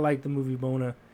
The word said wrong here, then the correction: Moana: Moana. – The, Moana. See, I don't even say it right. I just thought it was Mona.